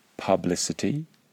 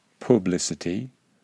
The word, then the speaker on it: publicity: Other words include publicity, not publicity, and republican, not republican.